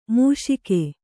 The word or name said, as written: ಮುಷ್ಕ